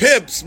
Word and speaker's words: Pips: The pits. I'm saying it right. Gladys Knight in the pits. – Pips, motherfuckers. – Yeah, P-I-P-S. – I said pimps at first. Gladys Knight in the pimps.